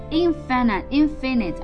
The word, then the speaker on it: infinite: 那既然 finite 是有限的， infinite 啊，我们发注意发音啊，这里变成 infinite 啊，这是 finite，这个叫 infinite 啊， infinite 表示无限的，那它的名词形式 infiniteness 啊，无限性。